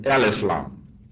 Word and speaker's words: Al-Islam: The proper way to pronounce this, the name of our religion, is Al-Islam.